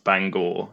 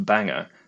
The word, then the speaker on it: Bangor: I sure did, and it was the sort of periodic reminder I get whenever Bangor is mentioned in my presence that it's not pronounced Bangor, it's pronounced Bangor,